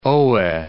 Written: Oe

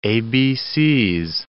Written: ABCs

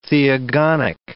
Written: theogonic

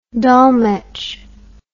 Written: Dolmetsch

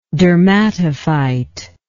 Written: dermatophytic

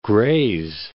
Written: Grays Harbor

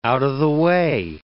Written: out-of-the-way

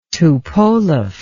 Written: tupolev

